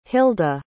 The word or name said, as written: hylda